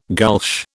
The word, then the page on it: gulch: British English